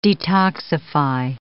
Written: detoxify

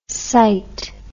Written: cyt

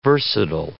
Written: versatile

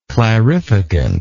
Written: clarificant

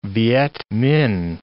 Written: Vietminh

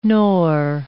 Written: nor

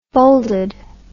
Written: folded

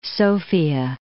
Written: Sofia

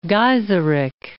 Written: Gaiseric

